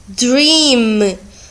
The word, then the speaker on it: dream: D, or, E, A, M, dream. Sempre que eu tenho EA, esse EA se pronuncia como I. Então, dream se pronuncia dream.